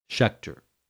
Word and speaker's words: Schaecter: David Schechter, his last name is spelled S-C-H-A-E-C-T-E-R. Schechter.